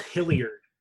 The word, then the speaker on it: Hilliard: Yeah, those can you spell Hilliard? – Hill. – Like Ike Hilliard? – Yeah, like Ike Hilliard. – Hill-Iard.